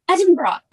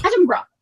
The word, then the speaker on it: Edinburgh: You have to say Edinburgh, okay? It's like a bra. – Edinburgh.